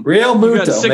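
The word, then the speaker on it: Realmuto: Harper, Real Matuto, Baum, Didi. And then, like, boom. – Real Muto, mate. What is it with you and names? Yeah. Real Muto.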